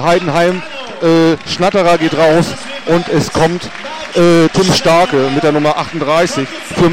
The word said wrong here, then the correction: Skarke: Und Skarke, Entschuldigung, nicht Starke, sondern Skarke, ich falls ausgesprochen,